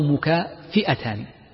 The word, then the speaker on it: مكافئتان: في نطقها هناك نطقان، إما بفتح الفاء أو بكسرها، مكافأتان أو مكافئتان، وكلا النطقين صحيح، ولكن المحدثين ينطقونها بالفتح ويختارون ذلك.